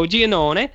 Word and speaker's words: Giannone: The first one was called Madman by Joe Giannone. Is that how you say it, Jared? – Yep. – Joe Giannone.